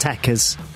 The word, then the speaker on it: tekkers: Trekkers. – It's pronounced Tekkers.